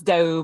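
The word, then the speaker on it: D'Aoust: It's Doe, but don't worry about oh.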